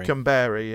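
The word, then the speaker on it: Kamberi: I've got no opinion on Camberi, whatever is. How do you pronounce it? – Camberi, yeah.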